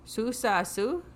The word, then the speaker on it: susasu: Susasu. Or mistamik. Yeah, something like that. But the way susasu is spelt, it's all long vowels. So it's S, long O. S long A.